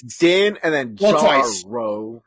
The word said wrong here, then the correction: Din Djarin: Din Djarin is how it's pronounced.